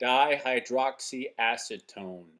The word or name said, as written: Dihydroxyacetone